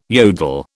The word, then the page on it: yodel: British English